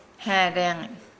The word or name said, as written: haa laangi